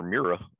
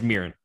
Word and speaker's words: Murin: Murin? Is that right? – You asking the wrong guy. I called her Mira. – That's definitely not it. – Sounds like I couldn't even say it. – It's Murin.